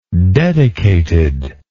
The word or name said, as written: dedicated